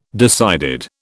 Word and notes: decided: British English

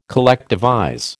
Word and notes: collectivize: American English

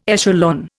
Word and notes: echelon: Indian

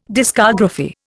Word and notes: discography: Indian

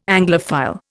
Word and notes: Anglophile: Indian

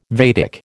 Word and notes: Vedic: American English

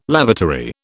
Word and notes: lavatory: British English

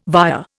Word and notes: via: Indian

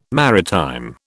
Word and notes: maritime: British English

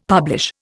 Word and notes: publish: Indian

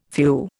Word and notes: few: Indian

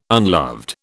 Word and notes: unloved: British English

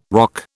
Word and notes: ROC: British English

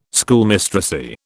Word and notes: Schoolmistressy: British English

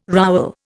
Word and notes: rowel: Indian

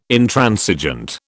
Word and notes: Intransigent: British English

